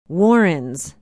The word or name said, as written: warrens